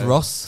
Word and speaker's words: rosé: Because even now, when I've had far too much rosé... I've had one too many aperitifs. – It's pronounced Ross. There's a stress at the E.